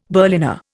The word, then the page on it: Berliner: Indian